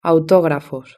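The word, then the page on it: autografos: Spain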